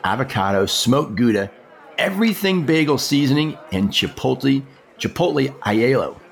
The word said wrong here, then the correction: aioli: How do you pronounce that? Aiello? Aiello? – I'm a little aioli. Aioli, I think it is. Aioli. – There you go.